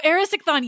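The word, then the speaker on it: Erysichthon: And obviously, Demeter does have those myths because he has the Now, I can never pronounce that, even in Greek, it's very hard. Erichthysion? – Oh, Erisichthon, yes!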